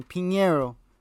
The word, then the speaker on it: Pinheiro: I found out how to say his name. Pinheiro.